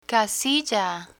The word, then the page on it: casilla: spanish